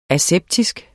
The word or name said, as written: aseptisk